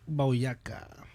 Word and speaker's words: Boyacá: La batalla de Boyacá. – Boyacá. – Boyacá. – Lleva acento en la A. ¿En cuál? – En la última A.